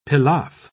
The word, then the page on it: pilaf: British English